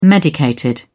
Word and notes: medicated: British English

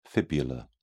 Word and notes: fibular: British English

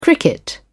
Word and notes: cricket: British English

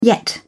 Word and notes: yet: English; British English

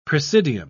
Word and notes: presidium: British English

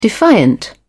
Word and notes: defiant: British English

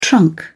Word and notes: trunk: British English

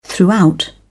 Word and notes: throughout: British English

